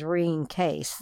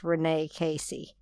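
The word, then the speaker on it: Rene Caisse: It looks as though it's R-E-N-E. French-Canadian pronunciation is Reen Case. The way it's written, it looks like it's Renee Casey. And Essiac is her name, her last name, Casey, spelled backwards. So it's E-S-S-I-A-C.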